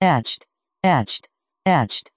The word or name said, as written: etched